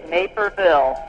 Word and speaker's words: Naperville: And am I pronouncing, is it Naperville or Naperville? – It is Naperville.